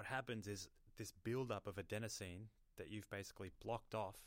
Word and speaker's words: adenosine: A lot of people don't realize that what caffeine is actually doing to their brain is there's a thing called adenosine in your brain. I hope I'm pronouncing that properly. – Yep.